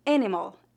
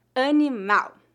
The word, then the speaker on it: animal: Observe que a sílaba tônica de palavras terminadas em L é no final. Animal. Lembre-se de que a letra L é pronunciada como um U fraco em final de sílabas ou final de palavras. Faça de conta que a letra L no final é a letra W.